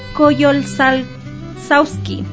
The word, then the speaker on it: Coyolxauhqui: la diosa Coyolxauhqui, si no me equivoco, sí. Está bien pronunciado, o Matzli, que era representada por la luna.